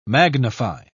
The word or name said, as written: magnify